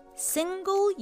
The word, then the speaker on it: single: Single use Yes, sounds great シングルではなく single るはあまり発音しませんね single use.